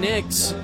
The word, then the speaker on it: Niksch: Peyton Nix is back on the mound. Nix spelled N-I-K-S-C-H, but pronounced like the NBA champion New York Knicks.